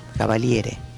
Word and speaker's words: cavaliere: ¿Cómo se dice caballero en italiano? – Caballero. Cavaliere.